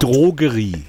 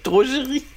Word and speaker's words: Drogerie: Drogerie ist auch so ein schönes DDR-Wort. – Drogerie. – Drogerie. – Entschuldigung. – Ja, aber Mann. Das ist ja die korrekte Aussprache in Ostdeutschland.